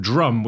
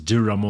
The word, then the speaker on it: Djrum: And next up, a track from Djrum, which is actually spelt D J R U M. I think I've tried to pronounce it as Durum or thing in the past.